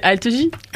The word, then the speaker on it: Algie: Non, c'est Algie. – Algie. – Bah oui, bah comment je le prononce? – Bah non, mais c'est comme Armanet.